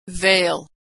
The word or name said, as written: vale